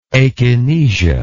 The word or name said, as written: akinetic